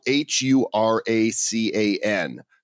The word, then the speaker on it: Huracan: Oh, it's it's spelled H U R A C A N. So that's uh, right. – Yeah. All right, the Huracan. Got it.